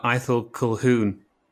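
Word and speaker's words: Ithell Colquhoun: So we're talking about Aethel Colquhoun today.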